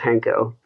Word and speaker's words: Hanko: But it's like Hank with an O, just Hanko.